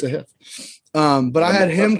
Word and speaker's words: Sahith: Like last week, I thought fucking Sahith Thagala. Apparently, that's how you say it now. It's Sahith. He came out and said it's Sahith.